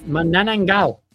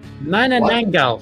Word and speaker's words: Manananggal: At number 8, we have Manananggal. No. Manananggal. What? There you go. Pronounce it properly, wow.